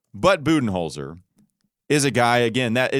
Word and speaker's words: Budenholzer: This says B-O-O, Budenholzer.